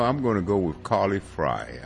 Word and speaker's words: Frey: It's spelled F-R-E-Y, and you would think that is Frey, but I'm going to go with Carly Fry. – I believe that's how she pronounces it. Like, well, fries, like French fry.